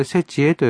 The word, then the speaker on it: 節会: 節は節という字に、えー、その会うという字で、節会と書くんですが、はい、まあ、これ節恵と読むんですが、節恵に食べるのがおせち料理。